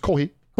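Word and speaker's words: kohi (コーヒー): Like, coffee is, like, just kohi.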